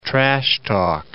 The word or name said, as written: trash-talk